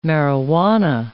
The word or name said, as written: marijuana